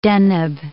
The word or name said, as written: Deneb